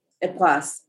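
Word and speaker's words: Époisses: Epoisse, E-P-O-I-S-S-E-S. So you might not even pronounce that last S. I can't remember. – Oh, letters. They don't like pronouncing those.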